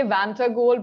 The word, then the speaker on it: Vantagold: Uh, I hope I pronounced that right. I say Vanta goal, but it's not oh. a real word, so it doesn't really matter, I feel.